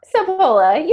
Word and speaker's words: Sapola: Sapola, you got it.